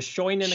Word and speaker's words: Scheunen: I'm not sure if I'm pronouncing it correctly, but the Shannon experiments? – The Scheunen experiments. Yeah, I don't speak German, but apparently Scheune means a barn in German.